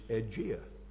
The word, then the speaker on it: Aegea: And they called that city, as we would pronounce it in English, Aegea.